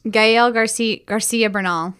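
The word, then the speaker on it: Gael García Bernal: What is Gael Garcia Bernal.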